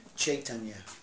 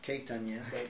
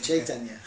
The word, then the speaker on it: Chaitanya: Just the C is pronounced a cha, as in Chaitanya. – Right. – Not Ketanya, but Chaitanya. Okay.